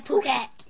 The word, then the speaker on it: Phuket: Oh, is that how you pronounce it? – Yeah, Phuket.